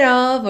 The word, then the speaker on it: of: Então, a log cabin made of earth and wood. O E de made não é pronunciado, o D virou rarara, of, OF, sempre com som de OV.